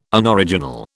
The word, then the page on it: Unoriginal: British English